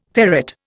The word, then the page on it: ferrate: Indian